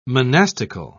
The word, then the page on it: monastical: British English